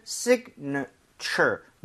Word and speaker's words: signature: 그래서 앞쪽 I의 강세를 좀 주면서 사실은 signature. 그러니까 살짝 나오지만 실제로는 발음을 빠르게 들으면 거의 signature. 거의 A 발음이 거의 안 나오게 돼요.